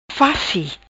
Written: Faci

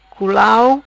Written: Culau

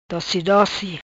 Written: Doci Doci

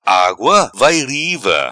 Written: Agua vai riva

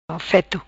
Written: Feto

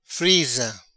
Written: Friza

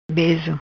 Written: Bezo

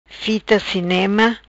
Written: Fita Cinema

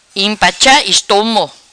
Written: Impacha Estomo

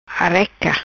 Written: Areca

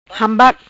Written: Hambac

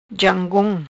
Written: Jangom

